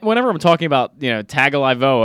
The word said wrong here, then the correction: Tagovailoa: or however you say it, Tagovailoa. He spelled it out phonetically for you, and you still got it wrong.